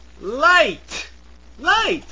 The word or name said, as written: light